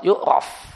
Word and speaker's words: yu'raf: Berdasarkan sabda Nabi kepada Fatimah binti Abi Hubaysh, dia mengatakan, Nabi mengatakan, jika darah haid, fa'innahu aswad yu'raf, atau fa'innahu aswadun ya'raf. Ada dua cara baca. Dua-duanya benar. Maka jika darah adalah darah haid, maka dia hitam.